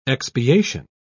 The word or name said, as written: expiation